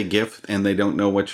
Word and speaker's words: GIF: and the guy who invented it says that they've always pronounced it with a soft G, GIF, like the peanut butter, and therefore that side of the argument says. The guy who invented it says it's GIF, therefore it's GIF. Whereas everybody else is like, if it's graphics interchange format, it's a hard G, it's GIF.